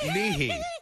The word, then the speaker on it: Nihi: In Nihi on Sumba. – How do you spell it? How do you spell it? – Where'd you get married? Nihi. – How do you spell Nihi? – N-I-H-I.